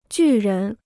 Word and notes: 巨人: jù rén

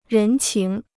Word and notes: 人情: rén qíng

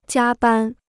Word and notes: 加班: jiā bān

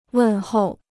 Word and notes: 问候: wèn hòu

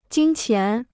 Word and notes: 金钱: jīn qián

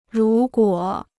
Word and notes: 如果: rú guǒ

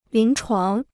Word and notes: 临床: lín chuáng

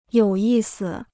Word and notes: 有意思: yǒu yì si

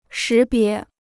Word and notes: 识别: shí bié

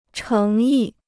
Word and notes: 诚意: chéng yì

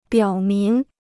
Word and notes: 表明: biǎo míng

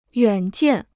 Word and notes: 远见: yuǎn jiàn

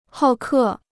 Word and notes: 好客: hào kè

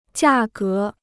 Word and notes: 价格: jià gé

